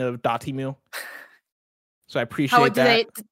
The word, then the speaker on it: Dotemu: It seems like everybody is agreed on dot emu is like the separated pronunciation people give. Dot emu.